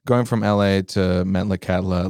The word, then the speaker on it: Metlakatla: So Metlakatla, right? Did I say that right? Metlakatla? – Yeah.